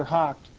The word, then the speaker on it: houghed: It's pronounced hocked, H-O-U-G-H-E-D. Okay, see that? They put a C-H in there. If you look it up in Webster's Dictionary, this word is pronounced hock, H-O-K. Okay, that's how it's pronounced.